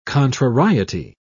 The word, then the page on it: contrariety: British English